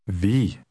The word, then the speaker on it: vi: Vi. Vi. – The I in Norwegian is pronounced like the double E in the English word see.